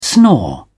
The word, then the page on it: snore: British English